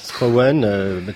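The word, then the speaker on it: Schrauwen: Et alors, comment vous dites Schroven? – Schroven, comme vous dites Scarbeck, vous voyez, c'est la prononciation flamande, puisque Olivier Schroven est flamand.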